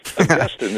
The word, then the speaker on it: Augustine: Now, that is not to be confused by what we have down here in the South, which is Augustine grass. But Augustine is the way theologians like to pronounce it, so we'll stick with that.